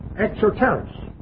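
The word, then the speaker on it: exoteros (ἐξώτερος): Exoteros. We get our word esoteric and exoteric from it in the English. But exoteric, E-X-O, long O, T-R-O-S, exoteros.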